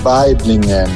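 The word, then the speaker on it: Waiblingen: Si dice Weiblingen.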